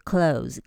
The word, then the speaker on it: close: 好，那本周我们来看这两个字 close 跟 close。你有发现吗？它的拼法一样，但是发音不一样哦。好，我们先来看动词的 close。动词 close， close the door，关上，关起来。